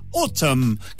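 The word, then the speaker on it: ATUM: Resulta que el más reciente trabajo de estudio de Smashing Pumpkins es una obra conceptual llamada Autumn, que se pronuncia como decir otoño en inglés, pero se escribe A-T-U-M y está dividida en tres partes o, mejor dicho, tres actos.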